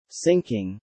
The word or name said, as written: syncing